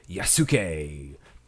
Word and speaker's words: Yasuke: I watched the Netflix original anime, Yasuke, executive produced by Lakeith Stanfield, Flying Lotus, and Deshaun Jackson. – There we go. Yes. How do you spell that? – Y-A-S-U-K-E.